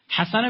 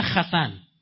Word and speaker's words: حسن: یا بعضیشون انقدر احمقن چون اونا تو زبان خودشون تو یهودی های اشکنازی هو خد تلفظ میکنن. حسن هم میگه خسن.